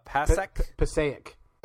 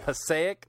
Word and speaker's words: Passaic: But the article, the big headline for the Huffington Post is 10-inch Pacu, testicle eating fish caught in how do you pronounce that? – Passaic. I'm from New Jersey. I can help. – I knew you were. Yeah. Passaic, New Jersey.